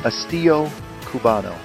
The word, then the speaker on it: Estilo Cubano: Estilo Cubano.